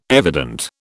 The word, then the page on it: evident: British English